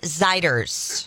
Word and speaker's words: Zeiders: Warren Zeiders. – Zeider. Like glider.